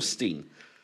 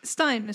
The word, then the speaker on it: Frankenstein: Oh, is it Frankenstein or Steen? – Stein. Is Frankenstein.